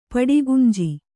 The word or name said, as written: ಪಡಿಗುಂಜಿ